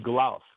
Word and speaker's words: Glaus: Last name is Glaus, which is also a Swiss-German name, G-L-A-U-S. Franz Glaus.